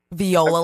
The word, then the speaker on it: Viola: Viola, like the instrument. – Viola. It's spelled like Viola.